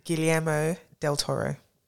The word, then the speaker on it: Guillermo del Toro: His name is Guillermo del Toro.